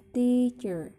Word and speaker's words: teacher: Yang di sini yang ditekankan yaitu yang t. Jadi bacanya teacher.